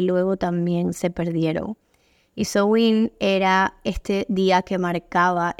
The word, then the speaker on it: Samhain: Y Sowin, que se escribe totalmente distinto, se escribe como Samaín, si alguien lo leyera. Cuando yo lo leía originalmente pensé que era así. Se pronuncia Sowin y como mencionaba, es una tradición celta.